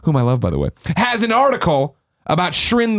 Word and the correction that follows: Shiren: the Wanderer. Did I say that right? Shiren, the Wanderer, being the last great Vita game.